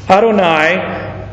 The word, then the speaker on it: Adonai: Adonai is what we would say. Now this has a preposition in front of it, to my Lord, so it's Adoni. But the pronunciation between Adonai and Adoni is just one little dot.